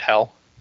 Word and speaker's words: Hel: Well, there's also a character in Norse mythology named Hel. – Isn't that with just one L? One yeah. Hockey stick? – It is, but it's still pronounced the same.